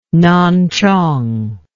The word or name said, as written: Nanchong